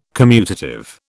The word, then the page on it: commutative: British English